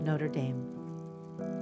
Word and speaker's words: Notre Dame: The pronunciation of the University of Notre Dame is the U.S. pronunciation, University of Notre Dame.